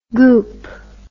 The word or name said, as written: goup